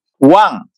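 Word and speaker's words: Wang (王): Wang. Not Wang. Wang. – Wang. – Yes. – So the G is kind of swallowed up in the nanation, the N, Wang. – Wang. Yes. – Wang.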